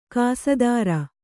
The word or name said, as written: ಕಸದಾರ